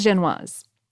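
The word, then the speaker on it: Génoise: Am I saying that right? I'm not. I think it's Genoise. – I think so. I'm with you.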